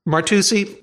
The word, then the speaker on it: Martucci: Martucci, let me ask you a question. You appreciate that it is very correct for CC in Italian to be pronounced CH.